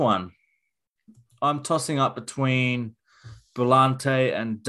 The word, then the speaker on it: Brillante: Brilliante. Brimmer. – Brilliante. – Daggers. How do you pronounce it? – Well, it's just Brillante. – It is, isn't it? Brillante.